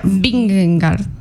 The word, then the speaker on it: Vingegaard: ¿Cómo se pronuncia? – Vingegaard. – Sí, Vingegaard.